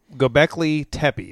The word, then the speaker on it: Göbekli Tepe: Göbekli Tepe is how you say it, but it's such a weird.